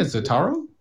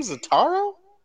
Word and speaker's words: Zatara: Yeah, Zatara. – Yeah. – Is that how you say his name, Zatara? – Yeah.